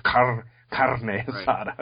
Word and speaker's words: carne asada: And the one's trying to get the other one to pronounce it like, you know, like his with this Hispanic pronunciation, rolling the R's, carne asada.